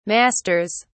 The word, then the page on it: masters: British English